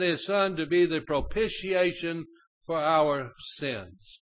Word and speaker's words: propitiation: And He sent His Son to be, and it's pronounced propitiation. That's just the way it's pronounced. I had to be taught.